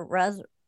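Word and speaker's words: Raz: Yes, I was going to say you mentioned Joseph Rez, and uh, is it Roz or Rez? Joseph Rez. – I've heard both.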